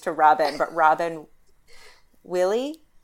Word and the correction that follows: Wille: Wille. Fix it, Megan. – Yeah. Veal? – Veely. – Okay. Veely. Good.